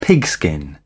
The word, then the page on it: pigskin: British English